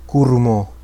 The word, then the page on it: Curumo: Quenya